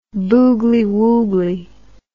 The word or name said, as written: boogily woogily